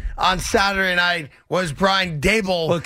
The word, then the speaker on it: Daboll: Yeah, so I guess that came out of the press conference today that we've all been mispronouncing his name. It is not Brian Daboll. It's Brian Dable.